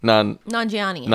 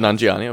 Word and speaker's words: Nanjiani: Nanjiani. – Nanjiani.